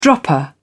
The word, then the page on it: dropper: British English